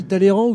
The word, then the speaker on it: Talleyrand: Alors, on dit Talleyrand ou Talleyrand, ça dépend des gens. Vous dites quoi, vous? – Si on veut avoir l'air très au courant, on dit Talleyrand. – On dit Talleyrand. – La prononciation de l'époque.